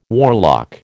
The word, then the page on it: warlock: American English